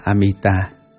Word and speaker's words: Amita: Chữ A-di-đà là tiếng Phạn Brahma ở Ấn Độ, nguyên âm đọc là Amita,